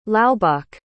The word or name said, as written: laubach